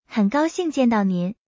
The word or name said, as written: 很高兴见到您